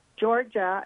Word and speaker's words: Georja: Georgia is with a J. It's GEORJA.